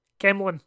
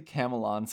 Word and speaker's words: Camelon: Camelin. Camelin. But that's the thing, is like, you talk to 10 different people in Camelin, you'll get 10 different ways to pronounce it. – No, it's definitely Camelon.